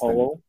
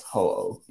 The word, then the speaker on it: Ho-Oh: I've never been able to pronounce that damn Pokemon's name right. It's Ho-Oh. – Yes, Ho-Oh. Yes, I caught Ho-Oh.